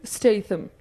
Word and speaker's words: Statham: So we looked at how to pronounce his name, and it is pronounced Jason Statham, just in case you're wondering.